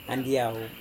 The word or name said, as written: andiau